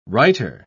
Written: righter

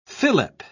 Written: fillip

